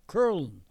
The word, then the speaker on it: Köln: Because the German word is K-O-U-M-L-A-U-T. Curlin is the way they pronounce it, but it's Cologne.